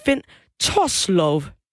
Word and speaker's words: Finn Torslov: Han hedder Finn Torslov. Kan vide, om jeg udtaler det rigtigt? Det rigtigt, ja.